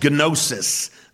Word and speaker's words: gnosis: Gnostic comes from a Greek word meaning knowledge, gnosis. They pronounce the G, we don't.